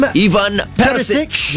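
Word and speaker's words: Ivan Perišić: Ivan Perisic.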